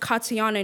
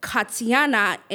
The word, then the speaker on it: Katiana: And why is my name pronounced Katiana in English, but Katiana in Haitian Creole?